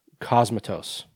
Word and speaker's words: Cosmatos: Cosmatos. There we go. I said it right. – Like comatose of the cosmos.